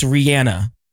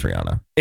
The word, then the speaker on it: Rihanna: Were you a part of, like, where did you fall when everybody in radio was like, it's Rihanna and not Rihanna? – I always knew it was Rihanna. – It's not. It's Rihanna. Rihanna? – Well, then Rihanna.